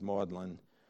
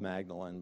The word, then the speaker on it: Magdalen: He says Magdalen, spelled Magdalene, but it's pronounced Magdalen. Why, I don't know.